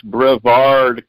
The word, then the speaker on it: Brevard: It's pronounced Brevard County. It's Brevard.